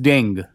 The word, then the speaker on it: dengue: You know, but dengue is still, I don't know how do we pronounce it? Is it dengue, dengue, dengua? What's the right way to do it? – Or is it just dengue? – It's dengue. It's just dengue.